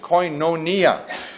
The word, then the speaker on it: koinonia: The New Testament term, usually translated fellowship, is the Greek word koinonia. And it looks like that in purple, translated into the English language. The little dash above the O means it's a long O in the Greek language, koinonia.